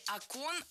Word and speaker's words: окон: Не окон, а окон. В единственном числе окно, окна, окно, окном – ударное окончание. А во множественном ударение переносим на первый слог. Окнами, окнах, окон.